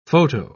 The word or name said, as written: photo